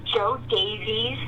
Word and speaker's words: Jo Daviess: It's Joe Davies County, and that's spelled J-O-D-A-V-I-E-S-S.